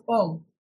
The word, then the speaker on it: om: Is it pronounced as om? – They pronounce om. – In China, Japan, everywhere? – Everywhere, it's om.